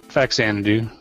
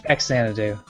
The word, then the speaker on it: Faxanadu: How do you pronounce it? – Faxanadu. – Okay, that sounds right. Faxanadu. – Yeah. – I was a little confused on whether the X was pronounced with a Z.